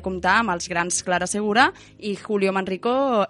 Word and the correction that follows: Manrique: Manrique perdó, per darrera vegada.